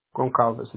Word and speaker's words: Goncalves: J-U-N-I. Hey. – All right, and her last name? – Goncalves, my last name. – Can you spell it for me? – G-O-N-C-A-L-V-E-S.